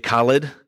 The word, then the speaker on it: Khaled: This song is produced, performed by secular artists like Dr. Dre and Kanye West, Eminem, and a guy named DJ Khaled.